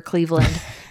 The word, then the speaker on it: Cleaveland: It's just at Piper Cleveland. That is spelled CLEAVELAND. – Absolutely. – Not like the city.